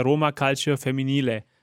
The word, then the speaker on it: Roma Calcio Femminile: Das, was du mir geschickt hattest, heißt ja Roma Calcio Femminile. – Genau, so wird es ja so. ausgesprochen wahrscheinlich. Ja. – Oder ah. verbessere mich. – Nein, nein, das, das passt schon.